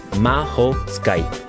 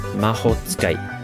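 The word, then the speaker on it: mahotsukai (魔法使い): And that word is mahotsukai. Mahotsukai. So it's like mahotsukai. Yeah, four syllables.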